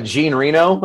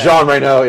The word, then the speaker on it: Jean Reno: G, uh, Gene Reno, as we say in America. – Gene Reno. Okay. – Jean Reno, yeah.